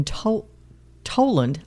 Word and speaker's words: Tolland: Janice lived in Toland, I hope I'm saying this correctly, T-O-L-L-A-N-D, Connecticut. – Mm-hmm. That sounds right.